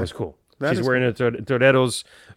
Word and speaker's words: Toreros: And I noticed she was wearing, I'm going to do it in the Spanish pronunciation, Toreros jersey.